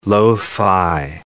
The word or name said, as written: lo-fi